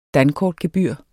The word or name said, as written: dankortgebyr